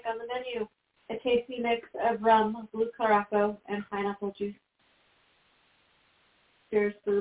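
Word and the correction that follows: curaçao: Pronounce it curacao. – Curacao it is. Sorry, I don't know why I said caraco.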